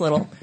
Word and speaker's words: Liddell: So Alice Little, L-I-D-D-E-L-L, but it's pronounced Little.